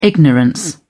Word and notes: ignorance: British English

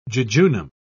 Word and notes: jejunum: British English

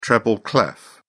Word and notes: treble clef: British English

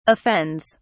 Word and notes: offends: British English

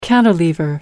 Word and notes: cantilever: American English

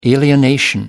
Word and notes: alienation: American English

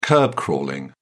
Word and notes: kerb crawling: British English

